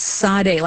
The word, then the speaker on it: Sade: But he'll say to remember it, it is Sade. Like, think of Jade, Sade, Sade. Okay.